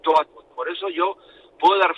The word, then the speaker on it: Dortmund: Dortmund.